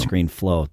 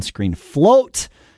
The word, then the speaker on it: Screen Float: So, I mispronounced it last week as Screen Flow, but it is Yoink and Screen Float.